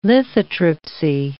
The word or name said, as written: lithotripsy